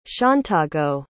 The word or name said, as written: shauntago